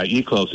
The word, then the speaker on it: Eclos: Yeah, Eclos. It's spelled E-C-L-O-S.